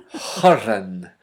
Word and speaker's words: Jarlin: Yarlin Garcia. Maybe it's Jarlin, but I believe the J is silent.